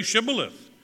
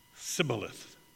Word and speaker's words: shibboleth: 'Then say shibboleth.' And he said, 'Sibboleth.'" Some kind of almost a lisp, for he could not pronounce it right.